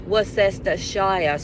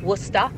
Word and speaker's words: Worcestershire: Now, if I'm not mistaken, maybe some other fellow expats might be able to confirm this, but if I'm not mistaken, it's actually pronounced Worcester. They don't pronounce the Cester. It's just Worcester sauce.